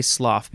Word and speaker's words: slough: I'm going to say slough because I'm pretty sure that that's how you say it. S L O U G H.